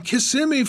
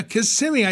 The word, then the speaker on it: Kissimmee: Let's go to Bule from Kissimmee, Florida. Kissimmee, I said it right because I've got a lot of friends there.